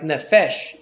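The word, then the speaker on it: nefesh (נפש): I have no idea why, but like there's a word, the letter in Hebrew for P sometimes gets pronounced like F, like nefesh, the word for soul. There's the P word, I forget how it's called in Hebrew, but in that word, the P becomes has an F sound, so it's called nefesh.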